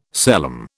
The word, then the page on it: celom: British English